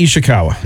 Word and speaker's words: Ishikawa: And then, was it Ishikawa? Am I saying that right? – Yeah, Kenny Ishikawa. Yep.